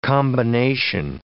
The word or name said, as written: combination